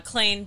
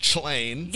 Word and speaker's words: Clane: And so it started out as Clane, the organic improv duo, Clane being a portmanteau of Chloe and Lane. – It's the most. – Is that what the word portmanteau means? – Yeah. And and it's often mispronounced Chlain.